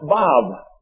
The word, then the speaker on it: Vav: It's pronounced Vav, V-A-V. This letter symbolizes in English as well as in Hebrew, it symbolizes a nail. N-A-I-L, a nail.